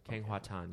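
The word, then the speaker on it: Kheng Hua Tan: Actress's name is Kang Hu Tan. That was my attempt at this pronunciation. – Yes. – Which I think I did it. – Kang Hua Tan.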